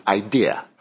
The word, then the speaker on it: idea: I'm sorry, I said that wrong. Idea is not an R. There's no R after idea. It's idea.